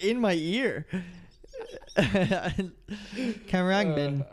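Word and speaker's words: Khruangbin: How do you say it? Corungbin. Corungbin. Pretty straightforward once you know. Corungbin.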